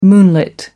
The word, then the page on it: moonlit: American English